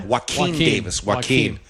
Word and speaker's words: Joaquin: So more realistic than you'd want to see, and that was Jaquan Davis, right? – Yep. – Joaquin Davis, like Joaquin Phoenix, spelled the same way.